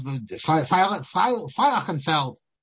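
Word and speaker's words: Feilchenfeld: Then he has a second letter again to, the Rav of Feilachenfeld. That's how you pronounce it.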